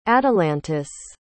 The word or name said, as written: atalantis